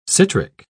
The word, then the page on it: citric: British English